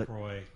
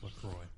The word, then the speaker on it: La Croix: It's La Croix. – They say La Croix? – The company okay. – itself. – It's like Manchac here in town? – That's on their website. It's pronounced La Croix.